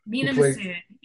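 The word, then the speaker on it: Mena Massoud: At the hands of, I hope I pronounce this correctly, Mina Massoud. – Mina Massoud, yes.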